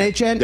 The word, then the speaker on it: Achane: So let me get his name right. Devin Achane. – Yes.